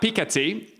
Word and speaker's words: Piketty: The French economist Thomas Piketty, a pronunciation that is correct and yet I still disagree with, has argued the pension reforms are unfair.